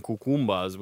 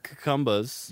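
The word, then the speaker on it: Kekambas: It's K-E-K-A-M-B-A-S. And while I will admit that is not very far and sounds phonetically... – It's not bad. – Like a little bit like cucumbers.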